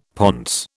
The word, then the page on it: ponce: British English